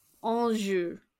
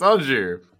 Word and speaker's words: Anjou: It's not pronounced A, it's pronounced O. – Anjou. – Yes, it's Anjou.